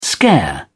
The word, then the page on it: scare: British English